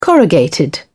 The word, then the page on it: corrugated: British English